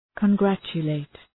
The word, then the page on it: congratulate: kən’grætʃə,leıt